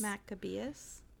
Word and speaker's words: Maccabeus: A veritable warrior of your people, like him whose name you bear, Simon Maccabeus. Did I say that right? Yes. – Maccabeus? – Yeah.